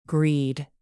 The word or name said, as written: greed